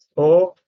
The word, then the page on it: o: Sanskrit